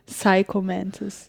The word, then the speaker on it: Psycho Mantis: Erzähl mal von dem Bossgegner, den ich total faszinierend finde, den Psycho Mantis. – Ach so, hier von Metal Gear Solid. Jetzt habe ich auch Psycho mit P ausgesprochen. Sorry, Psycho Mantis.